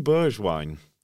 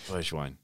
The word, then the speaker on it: Bergwijn: Should we start with Steven Bergwijn? – Let's. – Have I pronounced that correctly? – I think you have. – Yeah, Bergwijn.